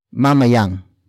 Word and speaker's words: mamayang: It is answered with a complement phrase, starting with mamayang.